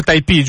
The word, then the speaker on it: Typee: Allora, intanto andiamo per gli ascoltiamo gli ascoltatori, si scrive TYPEE che magari lo vogliono trovare su internet, si pronuncia Taipei, giusto? Dico bene? Sì, sì è il primo,